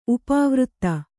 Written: ಉಪವೃತ್ತಿ

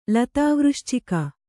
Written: ಲತಾವೃಶ್ಚಿಕ

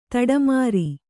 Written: ದಾಟುಮರ

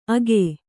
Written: ಅಗೆ

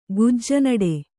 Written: ಗುಜ್ಜನಡೆ

